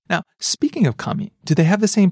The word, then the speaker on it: kami: Different region have different pitch accent, but in Tokyo area, we say kami. – Rising. – そうです。 – Starts low and goes up on the second syllable.